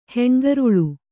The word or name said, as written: ಹೆಣ್ಗರುಳು